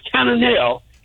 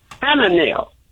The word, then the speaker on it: Chananeel: You know, the name is Cananel. It's spelled Chananeel. – Okay. – In the Bible, it's pronounced with an H, Cananel.